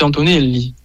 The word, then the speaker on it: Antonelli: mais effectivement, c'est Antonelli. – Ah voilà, Antonelli. C'est un peu l'italienne, en fait, finalement, hein, comme on le prononcera en italien.